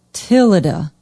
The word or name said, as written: tilleda